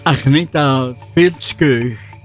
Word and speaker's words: Agneta Fältskog: Op zijn Zweeds uitgesproken, Agneta Fältskog.